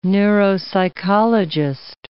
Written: neuropsychologist

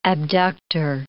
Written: abductor

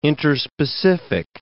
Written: interspecific

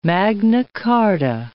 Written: Magna Charta

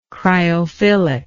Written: cryophilic

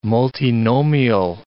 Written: multinomial

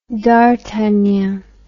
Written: dartagnan

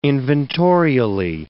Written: inventorially

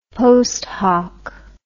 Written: post hoc

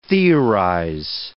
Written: theorize

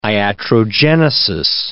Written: iatrogenesis

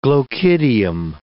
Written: glochidium

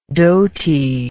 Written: dotiness

